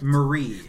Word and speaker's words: Marie: Betsy Brandt, of course, who played Mary. – Betsy Brandt, who is someone who was like a one-note character. – Marie, goddamn it.